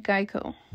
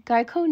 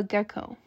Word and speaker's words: Geico: Their advertising agency, Martin Agency, came up with the idea of a gecko, which was made from the mispronunciation of their name. Many people thought the name was actually Gecko.